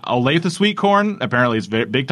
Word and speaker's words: Olathe: O-L-A-T-H-E. How would you pronounce that? – Olathe? – I thought it was Olaf. – Olaf? – But it was Olathe.